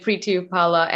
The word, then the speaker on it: Preity Upala: You did, Priti Opala.